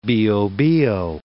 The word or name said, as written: Bio-Bio